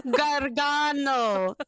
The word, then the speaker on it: Gargano: And I think you said it wrong. It's Sarah Gargano. – Gargano.